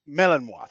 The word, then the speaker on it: Malinois: I think it's pronounced Malinois. – Yep. – Malinois. Thank you very much.